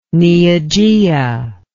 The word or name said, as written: neogaea